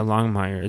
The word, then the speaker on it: Longmire: The other thing you made mention that you finished up was season 5 of Longmire, or how do you pronounce that? – Yeah, Longmire.